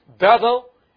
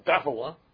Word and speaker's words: Bethel: But we all pronounce Bethel, huh? That's a pronunciation, but actually it's Beth means a house. El means God.